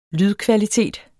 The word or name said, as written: lydkvalitet